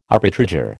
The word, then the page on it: arbitrageur: American English